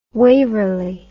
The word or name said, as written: Waverley